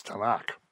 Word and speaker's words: Tanakh: And since Hebrew doesn't have any vowels, you can pretty much put whatever vowels you want in, but it's a set pronunciation, and it's Tanakh.